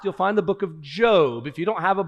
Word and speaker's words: Job: Job is indeed pronounced, for those of you new to the Bible, Job, not Job, though it's spelled the same.